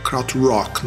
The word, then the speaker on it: Krautrock: Kraut Rock, no caso, pronunciado em inglês corretamente e não tão tosco quanto eu pronunciei antes.